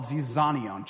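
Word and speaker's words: zizanion (ζιζάνιον): It's called zizanion.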